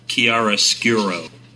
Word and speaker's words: Chiaroscuro: The magazine is pronounced Chiaroscuro. Chiaroscuro. That's it. I didn't name the bloody thing, but there you go.